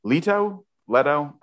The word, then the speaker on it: Leto: Paul is joined by his father, Duke Leto, in Leto? Leto? I'm just going with Jared Leto pronunciation here.